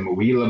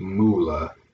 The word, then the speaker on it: Mula: We've said Muila before. Looks like and it sounds cool, but it is JD Mula.